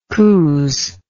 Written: cooze

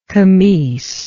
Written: comice

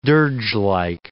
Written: dirgelike

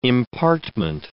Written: impartment

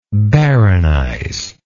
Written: baronize

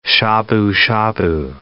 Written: shabu-shabu

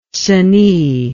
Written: cenis

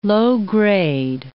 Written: low-grade

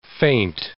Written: feint

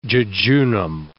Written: jejunum

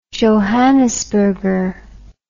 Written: johannisberger

